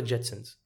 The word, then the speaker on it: Jetsons: ليه آل جيتسنز؟ ليه مو آل جيتسون؟ – هما تنطق جيتسنز